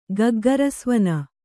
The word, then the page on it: ಗಗ್ಗರಸ್ವನ: kannada